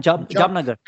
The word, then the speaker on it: Jamnagar: Jamnagar.